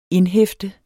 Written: indhæfte

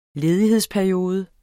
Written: ledighedsperiode

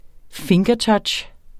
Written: fingertouch

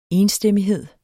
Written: enstemmighed

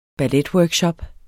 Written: balletworkshop

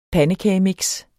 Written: pandekagemix

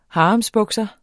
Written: haremsbukser

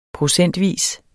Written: procentvis

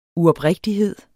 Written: uoprigtighed

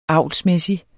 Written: avlsmæssig

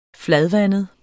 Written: fladvandet

